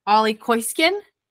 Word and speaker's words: Ole Keusgen: We're so excited to be joined by acting head coach of the University of Virginia Cavaliers, Ole Keusgen. Did I say that right? – That was fantastic.